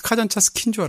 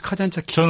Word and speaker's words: 카잔차키스: 저는 니코스 카잔차스키인 줄 알았어요.